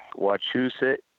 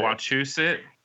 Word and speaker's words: Wachusett: Wachusett. Go with me, because I know I want to train you how to say this. – Wachusett.